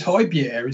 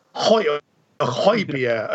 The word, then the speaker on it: Højbjerg: I think that is the correct pronunciation. – I think it's Højbjerg, isn't it? – Højbjerg, okay. Yeah.